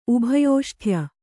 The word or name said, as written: ಉಭಯೋಷ್ಠ್ಯ